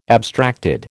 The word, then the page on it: abstracted: American English